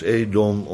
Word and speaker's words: Edom: Edom is eigenlijk gewoon Adam, dat weet u, dat is dezelfde naam. Men heeft besloten dat het hier moet uitgesproken worden als Edom, omdat het inderdaad over Esau en de Edomieten zou gaan.